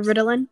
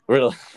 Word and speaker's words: Ritalin: I don't know if I pronounced that right, but Yeah, Ritalin. – Ritalin, excuse me.